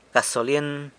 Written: gasolyen

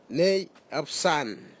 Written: neiapsan

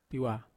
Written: tawa

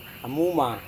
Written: amuma

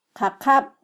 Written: qaqab

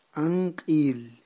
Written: anq'il

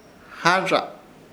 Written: Harrza

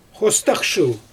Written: xostxʃɯl

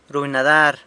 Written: runy nadar

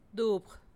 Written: dubg

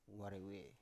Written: warewe

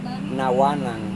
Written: nawanang

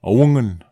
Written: augen

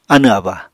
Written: anẁ-aba

